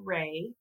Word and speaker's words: Wrey: And he named me Frankie Wrey, and my middle name is spelled W R E Y.